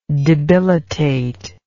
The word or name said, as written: debilitative